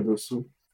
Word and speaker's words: Dossou: Bonaventure Dossou.